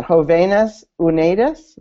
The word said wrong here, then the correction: Padres y Jóvenes Unidos: No, did I get it? Okay. She's laughing at me. – It's Padres y Jóvenes Unidos, Parents Thank and Youth United.